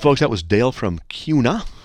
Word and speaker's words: Kuna: K-U-N-A. That's a kind of a neat word for a city. CUNA. It's not tuna, like tuna fish, but it's spelled T-U-N-A. No, K-U-N-A. You'd think it'd be CUNA. No, CUNA. You know, like tuna fish, but it's CUNA. I don't know why. It's the way it is.